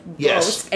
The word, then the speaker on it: Bocuse: So, B-O-C-U-S-E. – Oh, Bosque. – Yeah. – So his dad. Do you know about him? He's very well known in the neoclassical French cuisine. – Okay. – And he is Paul Bosque, and he is Monsieur Paul.